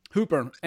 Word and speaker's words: Hooper: where we talked about Smokey the Bandit and is it Hooper or Hopper? I always fuck up the pronunciation. – Hooper.